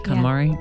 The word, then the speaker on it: KonMari: Now for the episode 33 like segment, the secret to actually enjoying your timeline, the KonMari? – That's how you say it? – KonMari.